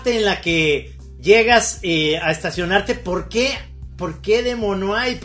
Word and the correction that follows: Des Moines: ¿Cómo se pronuncia? – Des Moines, como francés, ¿verdad?